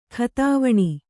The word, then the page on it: ಖತಾವಣೆ: khatāvaṇe